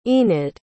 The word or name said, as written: enid